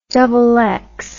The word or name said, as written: xx